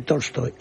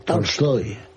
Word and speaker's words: Tolstoy: No es exactamente Tolstoy, es Tolstoy. – ¿Cómo? Lo dije. – Es Tolstoy, con A, una. – Embajador, lo dije yo así tal cual. Tolstoy. – Correcto, es una Tolstoy. Es una A que tira hacia el O, pero que es A.